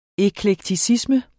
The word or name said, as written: eklekticisme